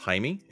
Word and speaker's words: Jaime: I've met Jamie, which, by the way, his name is pronounced Jaime in Spanish. That's how they pronounce what would otherwise be looks like Jamie in English.